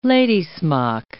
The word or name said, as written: lady's-smock